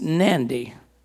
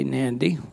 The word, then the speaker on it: Nadi: But it's Nandi, and it's spelled N-A-D-I, Nandi. They've got an extra N in there that's pronounced but not written.